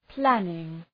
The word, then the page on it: planning: ‘plænıŋ